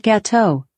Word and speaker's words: gâteau: I pronounce that gâteau, but I'm always gâteau.